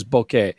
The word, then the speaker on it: bokeh: Okay, so let's talk about the first big thing, which has to do with lenses, which is bokeh. And the first issue is, how do you pronounce it, right? – You said it correctly.